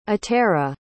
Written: atera

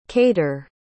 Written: qadr

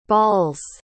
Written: bawls

